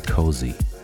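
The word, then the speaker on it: Koze: Internationally, I believe most people pronounce his name DJ Cozy. I like Kotze.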